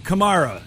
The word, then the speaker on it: Kamara: Some bad news for Saints running back Alvin Kamara, or Kamara, depending upon which way you pronounce it, as well as defensive back Chris Lemons of the Cincinnati Bengals.